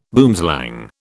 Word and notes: boomslang: British English

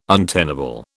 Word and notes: untenable: British English

